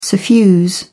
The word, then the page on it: suffuse: British English